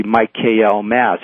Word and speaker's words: Michaelmas: which is called Michaelmas if you're English, but if you pronounce it right, it's actually Michael Mass.